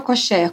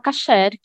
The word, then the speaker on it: kosher: Kosher ou kosher? Como é que fala? – Kosher. No hebraico é kosher, mas a gente fala kasher, que é a mesma coisa, né?